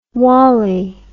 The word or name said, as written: wolly